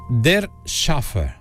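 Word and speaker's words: Der Schäfer: Se dice Der Schaffer.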